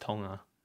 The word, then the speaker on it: Tonga: It's actually pronounced Tonga. Do you know that? – Tonga. – It really is. – How Tonga? – It's not. You don't say the G.